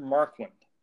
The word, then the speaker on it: Marquand: Directed by Richard McQuand. I think that's how you pronounce his name. Richard Marquand.